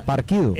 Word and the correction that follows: Hapkido: esta no, Hapkido, dije Hapkido, está bien que yo soy mula, pero sí sé que es Hapkido.